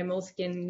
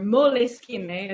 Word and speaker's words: Moleskine: And when I am too busy to take out my Moleskine notebook, or Moleskine, as I was recently corrected, you are supposed to pronounce it, à l'italienne,